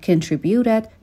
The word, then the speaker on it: contribute: و همچنین به تلفظ کلمه contribute دقت بکنین که در ابتدا صدای شوا رو داره.